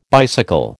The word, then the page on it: bicycle: American English